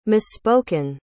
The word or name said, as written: misspoken